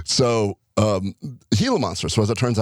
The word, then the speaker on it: Gila: As it turns out, there was a Canadian researcher that realized that the Gila monster, G-I-L-A, I think it's still pronounced Gila. – Yeah. – Or Gila monster, wherever you're from. – It's Gila.